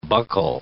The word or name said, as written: buccal